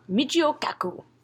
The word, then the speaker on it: Michio Kaku: And you, okay, so Miki Okaku, the guy that wrote the book I was showing you guys earlier. – Yeah. – I don't know if I'm saying his name right. Kaku? – Michio Kaku.